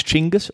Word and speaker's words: Chinggis: It Genghis, was Genghis. – Yeah. – Incorrect pronunciation. – Really? – That was my discovery this week, that we've been pronouncing Genghis wrong this whole time. It's Chinggis or Chinggis.